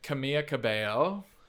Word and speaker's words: Camila Cabello: Next, we're going with Camila Cabello.